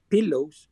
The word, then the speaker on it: PLLWS: Y ellos se llaman, bueno, el nombre lo pronuncian Pillows, pero es P-L-L-W-S.